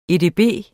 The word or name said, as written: edb